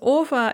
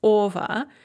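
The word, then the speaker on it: ova (ого): And of course, this ova is spelled o-ge-o, but pronounced ova.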